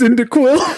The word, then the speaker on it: Cyndaquil: Quintessil! Okay. I need you to know that it's Cyndaquil. – Oh, damn it! What was I saying? Quintessil? I was close. I had all the syllables there. They were just in the wrong order.